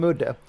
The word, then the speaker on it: Mudde: Hur uttalar du namnet? – Ja, det blir olika varje gång. men låt oss kalla honom Mudde. Tudde och Mudde.